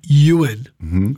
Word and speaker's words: Ewan: People who really deep down know me, my name's actually Ewan, which is E-W-A-N, which is a Welsh name. But if you pronounce it in Welsh, it's Ewan.